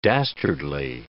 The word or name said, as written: dastardly